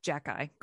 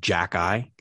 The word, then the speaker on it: Xhekaj: The reason why I'm confused with how you pronounce this is, if you aren't familiar with this prospect, his last name is spelled X-H-E-K-A-J. Phonetically, that's a nightmare.